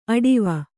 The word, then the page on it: ಅಡಿವ: aḍiva